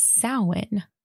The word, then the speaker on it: Samhain: So it's spelled Samhain, and everyone is like Samhain or Samhain, but that's wrong. It's Samhain because it's Gaelic.